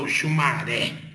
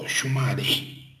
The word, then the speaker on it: Oxumaré: Normalmente, vocês aí falam Oxumaré, mas o africano fala Oxumaré.